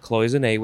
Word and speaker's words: cloisonné: Next is cloisonné. Cloisonné, you could also say cloisonné. Cloisonné. C-L-O-I-S-O-N-N-E.